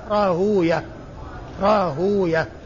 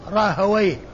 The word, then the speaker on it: راهويه: وراهوية هذه من الألفاظ المركبة التي اللغويون لهم فيها نطق والمحدثون لهم نطق آخر، فالمحدثون يأتون بها الهاء مضمومة والواو ساكنة والياء مفتوحة وبعدها هاء راهوية راهوية، وأما اللغويون فيجعلونها مختومة بويه راهويه، يعني آخره ويه، الواو مفتوحة والياء ساكنة وبعدها هاء، فالمحدثون يقولون راهوية وأهل اللغة يقولون راهويه راهوي.